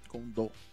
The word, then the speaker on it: Kondo: Eles dão a ênfase ali na última sílaba, né? Kondo.